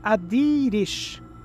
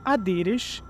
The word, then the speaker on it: Adiris: que indica que a pronúncia do nome, na verdade, é Adirish. Ou seja, Adiris antigamente se pronunciava Adirish e é assim que ela será chamada nessa nossa pesquisa de hoje.